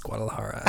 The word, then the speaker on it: Guadalajara: Girl, the J is silent. It's Guadalajara.